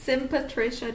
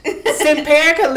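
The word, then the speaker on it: Simparica: Simperica literally looks like how it's spelled.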